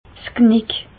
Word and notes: 's Knick: Bas-Rhin; Reichshoffen

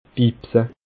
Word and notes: bibse: Bas Rhin; Schiltigheim

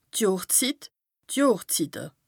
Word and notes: d' Johrszit, d' Johrszitte (pl): Bas Rhin; Strasbourg

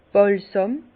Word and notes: Bàlsàm: Bas Rhin; Herrlisheim

